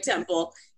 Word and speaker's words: Temple: Yes, but Temple is fine. There's too many last names to follow, so just go with Temple.